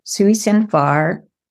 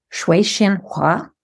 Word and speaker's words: Sui Sin Far: So Edith starts writing stories under a Chinese pseudonym, Sui Xin Far, or we could say Shui Xin Hua. That would be my best effort at a Mandarin pronunciation.